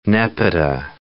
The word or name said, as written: nepeta